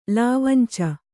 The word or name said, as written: ಲಾವಂಚ